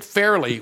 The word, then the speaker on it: Fairleigh: It's spelled the way it sounds, F-A-I-R, fair, Lee, L-E-I-G-H. – Oh, yeah, that's exactly what it sounds like. – Yes, it does. When you think Fairleigh, what do you think of? Not Farley, not Chris Farley. – No, F-A-I-R-L-Y is what I would have thought.